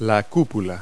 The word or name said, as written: cupola